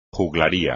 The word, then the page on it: juglaría: Spanish